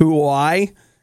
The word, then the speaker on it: Huawei: KFC has teamed up with Huawei.